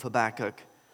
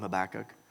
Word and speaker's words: Habakkuk: Some of you may, there's all kind of different pronunciations for his name, but I'll call him Habakkuk.